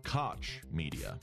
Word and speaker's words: Koch Media: It's pronounced Koch Media.